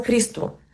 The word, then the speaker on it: Христо: В интерес на истината, когато говорим само за него, аз си позволявам да казвам Христо. И така неговото семейство поправи. И това е неговото българско име.